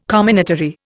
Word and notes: comminatory: Indian

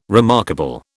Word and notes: remarkable: British English

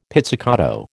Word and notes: pizzicato: American English

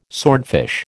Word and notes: swordfish: American English

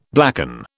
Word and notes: blacken: British English

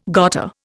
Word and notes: gotta: Indian

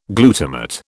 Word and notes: glutamate: British English

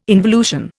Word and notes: involution: Indian

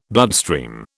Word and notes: bloodstream: British English

